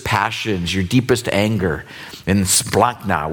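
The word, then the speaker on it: splachna (σπλάγχνα): It's pronounced splachna, which sounds more like a, I don't know, a German dessert.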